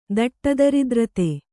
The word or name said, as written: ದಟ್ಟದರಿದ್ರತೆ